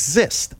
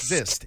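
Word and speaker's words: xyst: I don't know what year it was, but it's Zist. I came up with a word of the day, Zist, and it's in my book, too. Now, that's spelled X-Y-S-T, X-Y-S-T. So I try to do a simple thing, and what it is, it's simply a grove of trees.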